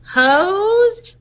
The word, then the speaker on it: Heaux: Her first two hits were Hi Bitch, and the other one was These Hoes, but it's spelled h-e-a-u-x.